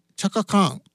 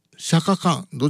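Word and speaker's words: Chaka Khan: 日本ではこのチャカカーンのチャカというのが定着しているようですが、本来アメリカでこのチャカ、 CHAKA を発音するときはシャカと発音する人も多いみたいですね。なのでチャカカーン、シャカカーン、どちらでもいいみたいですよ。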